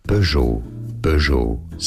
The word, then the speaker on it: Peugeot: Peugeot, silent T there. In English, Peugeot, from French Peugeot.